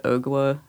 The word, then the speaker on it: Ogua: It's hard to pronounce, but Ogua, I think. It's like O-G-U-A.